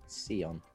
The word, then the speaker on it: Sion: Sion. – Oh, is it? – Yeah. – Oh, okay. – Even though it's spelled like Zion, it's Sion.